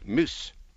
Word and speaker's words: mys: In Anglo-Saxon, it was spelt M-Y-S, pronounced muse.